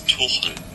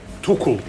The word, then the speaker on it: Tuchel: Yeah, and in all seriousness, we should do some quant on Mourinho and Thomas Tuchel. I assume I'm never quite sure how to pronounce it, but... – Thomas Tuchel. – There we go. – Tuchel.